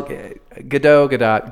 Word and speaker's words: Gadot: I thought she had it wrong. We were all saying it right. – Well, Gadot, Gal, sure.